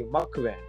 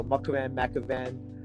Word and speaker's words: Macavan: I was like, there's no way my name is Macavan in my native tongue from Punjab in India, right? It's just the way the A is pronounced, it can't be that. So I started taking Sanskrit, and I realized that. I asked my parents, and it's actually Macavan. So that's my full name. So Macavan. Either way, the first few letters are M-A-K, and I grew up just being called Mac because of that.